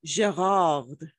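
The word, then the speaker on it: Gérard: That's how you say it in Francais. – Say it again. – Gerard.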